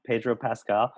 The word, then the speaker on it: Pedro Pascal: Pedro, Peter Pasquale. – Pedro Pascal.